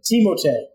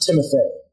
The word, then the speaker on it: Timothé: Timotei, not Timothée.